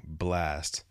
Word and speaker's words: Blxst: I figured out how to say his name, B-L-X-S-T, Blast, which I thought it was in the first place.